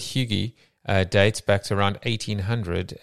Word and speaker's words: hygge: The other part, it says, in brief, hygge. Is that how you pronounce it? Hygge. – Close enough.